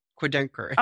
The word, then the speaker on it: Querdenker: I'm not even sure how to pronounce that. What what is that mm-hmm. and what does it mean? – Querdenker.